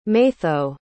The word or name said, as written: maitho